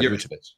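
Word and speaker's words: Vučević: Yeah, Vucevic. Yeah, I nailed that. I'm looking at his pronunciation on B-ball ref.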